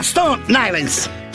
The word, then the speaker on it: Stone Island: Questi jeans della Stone Hansen. – Di Jim, che c'è la... – Questi jeans della Stone Islands.